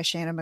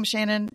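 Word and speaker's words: Seanan: I'm pronouncing her name Shannon. It's S-E-A-N-A-N. So I don't know. But I've been doing that for 20 years. So I hope I'm right.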